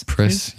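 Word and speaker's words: Pris: How do you say her name? Priz? – Priz, yeah.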